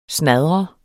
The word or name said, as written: snadre